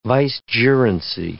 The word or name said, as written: vicegerency